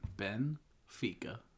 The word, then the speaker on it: Benfica: Added a syllable to Benfica. – What'd I say? – Benfica. – What's the? We said the same thing. – Benfica.